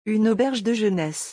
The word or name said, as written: Une auberge de jeunesse